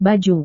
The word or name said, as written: baju